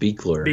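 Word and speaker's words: Buechler: Is it is it John Carl Butcher or something like that? I'm really uh, I think it's pronounced Beekler.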